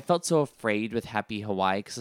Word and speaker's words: Hawaii: Hawaii. It's Hawaii. That's how you're supposed to pronounce it.